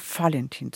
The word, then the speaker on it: Valentin: Aber hier ist es üblicher, Valentin zu sagen, mit F ausgesprochen.